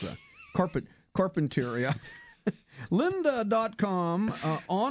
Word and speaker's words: Carpinteria: Carpinteria.